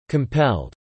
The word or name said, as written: compelled